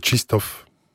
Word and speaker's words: Krzysztof: Il s'agit bien de, alors je vais le dire à la française, hein, Krzysztof Stysinski. En fait, ça se prononce, c'est la langue qui vient du polonais. C'est Krzysztof, c'est comme ça qu'on le prononce.